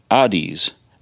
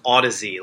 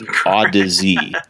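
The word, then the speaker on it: Audeze: So anyway, speaking of headphones, you wrote about the Audies, planar iSign in-ear headphones. – It's actually pronounced Odyssey, like Odyssey.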